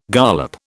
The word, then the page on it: gollop: British English